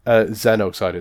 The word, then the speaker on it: Xenocider: Are you saying Xenocider or Genocider? – Xenocider. It's spelled with a Z.